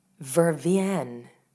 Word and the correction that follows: vervain: I don't know, I'd have to Google it, but I think Vervienne, and I'm sure I'm mispronouncing it in the same way I mispronounce croissant, but I feel like that might be, tea infusion. News just in, it's pronounced vervain.